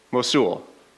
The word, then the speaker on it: Mosul: In northern Iraq, up here, close to Turkey, there's the city of, I don't know how to pronounce that, Mosul? Did I say that right? Mosul. Thank you.